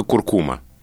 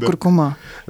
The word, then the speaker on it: куркума: Я очень люблю слово куркума. – Да, так вот, все словари настаивают, что правильно куркума. Я как абсолютно законопослушный, верящий словарям человек, это ударение выучил. И теперь я говорю только куркума.